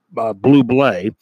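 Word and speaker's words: Bublé: Everybody says, you know, his name is, it's written, it looks like it's Bubble or something, but no, it's pronounced Blue Blay.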